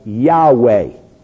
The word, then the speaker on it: Yahweh: what the pronunciation would have been, was Yahweh.